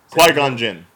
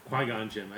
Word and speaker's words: Qui-Gon Jinn: The guy who played the Quan Jin character. – Yeah. – If I'm saying the name right, because I feel like I'm... – Qui-Gon Jinn. – Qui-Gon Jinn. I think Quan Jinn was Mortal Kombat, maybe.